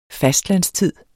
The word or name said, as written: fastlandstid